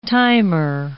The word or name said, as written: timer